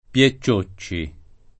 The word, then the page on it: piecciocci: piè ciocci